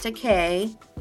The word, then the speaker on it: Takei: Yes, and it's pronounced Takei. like, I'm gay.